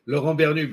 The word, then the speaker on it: Laurent Bernut: Laurent Bernut, but yeah, that was close enough. – Bernut, okay. Yeah, the T is silent.